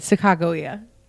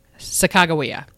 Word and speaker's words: Sacagawea: Sacagawea. – Sacagawea.